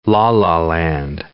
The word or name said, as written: la-la land